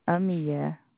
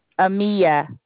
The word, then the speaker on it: Amia: Amia. – What is it? – Amia. – Could you spell that for me, please? – A-M-I-A.